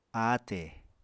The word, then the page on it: ate: Apatani